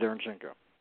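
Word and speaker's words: Derevyanchenko: The number two ranked fighter for the IBF is how do you pronounce his name? He's on the show. He trains with them. Derenchenko? – Yes, Sergey Derenchenko.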